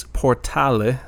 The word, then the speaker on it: Portale: So title of the album is Portale, so they do pronounce the E at the end.